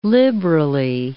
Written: liberally

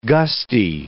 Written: gusty

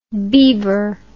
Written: bevor

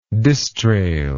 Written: distrail

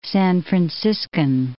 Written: San Franciscan

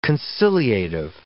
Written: conciliative

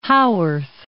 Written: Haworth

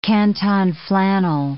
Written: canton flannel

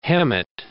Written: Hemet

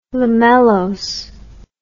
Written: lamellose

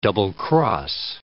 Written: double-cross

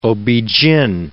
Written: ob-gyn

